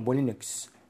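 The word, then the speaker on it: Molyneux: Molinux.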